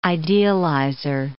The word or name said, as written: idealizer